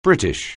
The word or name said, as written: British